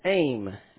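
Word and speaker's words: AIM (עם): And the answer to that question is that that word is AIM, A-M, long A.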